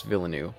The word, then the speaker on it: Villeneuve: I don't even know how to say it now. I usually know how to say it when I'm looking at it. Villeneuve? – Yeah, because it's French-Canadian, I think it's pronounced Villeneuve.